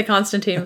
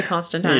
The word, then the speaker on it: Constantine: Yeah, I keep wanting to say Constantine, but they say Constantine. – Yeah. – I guess that's the way it was supposed to be pronounced.